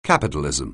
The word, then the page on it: capitalism: anglais